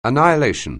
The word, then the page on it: annihilation: anglais